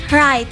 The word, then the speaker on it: write: Jadi huruf W-nya itu silent. – Oh, silent. – Jadi bacanya write. – Write. – Right. Yeah. Right. Kalau kita itu write. Right. W-nya tuh enggak kedengeran, ada yeah. Tapi enggak kedengeran yeah.